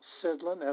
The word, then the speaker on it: Sidlin: I'm Michael Sidlin, S-I-D-L-I-N.